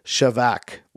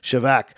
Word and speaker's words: shabach: And the last one, number seven, is the word shavak, which is spelled S-H-A-B-A-C-H, okay? But I'm trying to be fancy with my pronunciation, shavak.